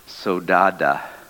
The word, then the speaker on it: saudade: It's actually S-A-U-D-A-D-E, but it's pronounced sodada.